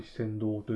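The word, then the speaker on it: 詩仙堂: ですけど、行って、その観光できる時間がだいたい 2時間とか 3 時間しかなくて、まあ、ちょっと何駅も回る時間はないなって感じだったので、一乗寺の四川堂というところをメインに見学というか観光しました。四川堂っていうのは、読む歌、詩ですね。詩の仙人の仙のお堂と書いて四川堂と読むんですが、紅葉とか雪景色が綺麗なところということで、有名なところで、当日の京都は雨模様で、雪、寒かったんですけど雪にもならず、雨が降ったり止んだり、まあ、ほぼほぼ降ってた感じですかね。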